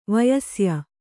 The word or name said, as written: ವಯಸ್ಯೆ